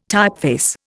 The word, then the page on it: typeface: Indian